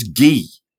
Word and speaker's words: Guy: His real name is Gee, by the way, and his I insist name, that you call him Gee, as Guy, in Guy de Maupassant. – He adopts the French pronunciation, does he? – He does.